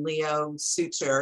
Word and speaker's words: Leo Suter: So your actors, Adelaide Clemens and Leo Suter, is that the way you pronounces it? – Yes.